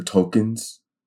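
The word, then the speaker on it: Tulkun: The official name for the whales is the Tolkiens. Tolkiens. Tolkiens. – Tolkien, like... Lord of the Rings? – Not spelled that way, but yeah, kind of. It's pronounced that way.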